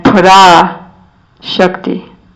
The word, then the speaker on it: Parashakti: And for the exact pronunciation, it's Pada Tattva and Pada Shakti.